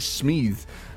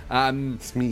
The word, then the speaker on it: Smeeth: By the way, that is the correct way to pronounce it, Natalie. You're absolutely right. That is Smeeth. Smeeth. But it sounds fantastic.